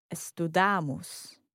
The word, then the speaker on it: estudam: So nós estudamos. – Nós estudamos. Mm-hmm. To say you study in the plural. – Vocês estudam? – Vocês estudam. So, attention in the pronunciation here. Estudam, not estudam.